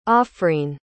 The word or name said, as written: afreen